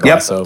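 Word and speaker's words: Glacéau: Glasso.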